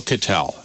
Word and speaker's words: Kittell: Cattell. And that last name is K-I-T-T-E-L-L. Not many people spell it right the first time.